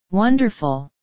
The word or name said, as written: wonderful